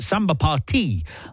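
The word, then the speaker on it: Samba Pa Ti: Sarah Pooley is next, wanting some Santana, a song I had to check the pronunciation of today. I've played it for years, never quite been sure about how it's pronounced. It's Samba Party, I believe. It means Samba for you.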